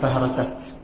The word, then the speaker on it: فهرست: ذكر الحافظ أن كلمة فهرسة إذا وقفت لا تقف بها، تقول هذه فهرست، توقف عليها بالتاء كذلك، وقفت أو وصلت تنطقها بالتاء.